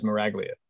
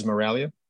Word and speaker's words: Miraglia: There's the English way and the way I grew up, which was Miraglia, right? There is an Italian pronunciation, which is Miraglia.